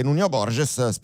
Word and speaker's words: Borges: Ha battuto Kecmanovic, al secondo turno affronterà il portoghese Nuno Borges, reputando che questa sia la pronuncia più autentica.